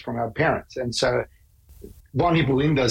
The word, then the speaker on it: von Hippel-Lindau: And you have been diagnosed with something called von Hippel-Lindau. Is that how you pronounce it? – Yeah, von Hippel-Lindau syndrome.